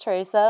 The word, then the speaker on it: Therese: Um, but yeah, so my name's Teresa, like very well said, Emma. Um, or pronounced, I should say.